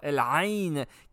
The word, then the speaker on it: El Ain: contre El Ain, qu'on prononce en arabe. C'est comme ça qu'on prononce ça, El Ain, qui veut dire l'œil en arabe.